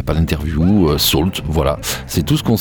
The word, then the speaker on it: Sault: Alors Salt, ça s'écrit S-A-U-L-T.